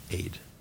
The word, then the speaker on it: Ede: E-D-E and Ravenscroft. In Ireland, that E-D-E would be pronounced Aid.